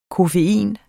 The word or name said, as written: koffein